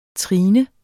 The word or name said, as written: trines